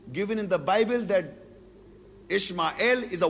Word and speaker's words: Ishmael: According to the Bible, and of course it's the Jews and Christians' faith, in Genesis 16 and verse 12, he, Ismail, or Ishmael, that is the biblical pronunciation in English, will be a wild man.